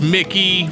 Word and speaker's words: Mickey: I said Mikey. It's Mickey, right? Is that right? – Mickey Guyton, yes.